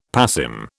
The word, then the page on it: passim: British English